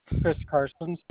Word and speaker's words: Chris Carson: Chris Carson, CHRISCARSON.